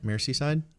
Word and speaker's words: Merseyside: Merseyside? – Merseyside, Liverpool.